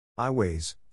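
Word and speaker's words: Aiways: iWays, pronounced iWays,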